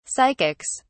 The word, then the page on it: psychics: British English